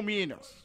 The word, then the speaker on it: Minions: Sem nada, só com Minions. Falei certo? – Falou.